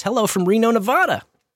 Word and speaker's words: Nevada: But there is a local Missouri town spelled Nevada, but is pronounced Nevada officially.